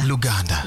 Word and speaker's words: Luganda: Luganda.